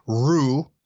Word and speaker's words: Roux: And big baby news today as Becky Lynch and Seth Rollins welcomed their new child, Rue. I'm pretty sure that's how you pronounce it because it's spelled R-O-U-X.